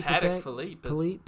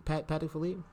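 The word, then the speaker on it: Patek Philippe: Yo, how do you say motherfucker's name? Paddock? Patek? Philippe Paddock Philippe. – Paddock Philippe.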